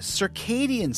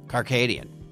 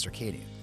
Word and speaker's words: circadian: According to this, this is the one thing that I am fascinated by, the circadian cycle. – Carcadian. – I've heard it as circadian. – No, you're saying it wrong.